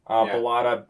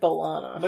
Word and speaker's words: Balata: Griff like Griff. Balata. B-A-L-A-T-A. – Oh, I heard Balana.